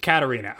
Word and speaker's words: Katarina: Her opponent's angel's name is Katarina. Am I saying that right? Katrina? Is it Katarina? – Katarina. Okay.